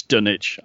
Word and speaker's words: Dunwich: Or how do we pronounce it? – It's Dunwich. I'm fairly... – Dunwich? – Yeah. – I like saying it with a witch, because then we get the witch idea in our heads.